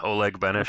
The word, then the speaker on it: Oleg Benesh: Did I say that right? – Yeah, that's good enough. I've been called far worse. – Come on, I have a long name too, and people mess it up. How should I say it? – Oh, that's fine. Oleg Benesh. Okay. Sounds good. – Oleg to me. – Benesh, yeah.